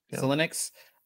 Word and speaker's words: Xilinx: Xilinx.